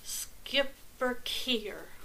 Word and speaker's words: Schipperke: The name is pronounced Schipperkeer.